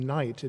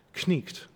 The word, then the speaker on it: knight: So rather than knight, it'd be neat, but you'd pronounce the K, kn, and then the end, kt, knicht.